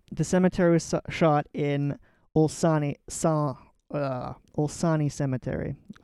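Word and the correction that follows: Olšany: I'm probably pronouncing that incorrectly. Okay. Olsani Cemetery. There's Olshani. – Olshani. – Yeah. – Okay, Olshani Cemetery.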